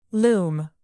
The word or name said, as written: loom